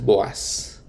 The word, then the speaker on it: Boaz: Y déjenme les menciono, les, les digo el nombre en inglés porque es más fácil de pronunciar que Boaz con dos O's. El nombre en inglés es Boaz. Entonces con A, B-O-A-Z. Entonces, discúlpeme, es más fácil pronunciarlo de esa manera que vos con dobles, dos os.